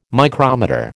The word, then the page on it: micrometer: American English